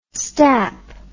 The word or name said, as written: stap